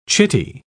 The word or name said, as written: chitty